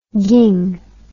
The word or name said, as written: ging